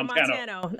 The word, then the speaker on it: Montano: It's just like the state with an O at the end. Okay, so Montano.